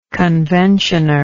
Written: conventioner

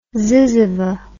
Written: zyzzyva

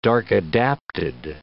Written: dark-adapted

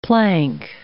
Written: plank

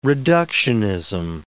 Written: reductionism

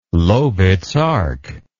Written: lowitz arc

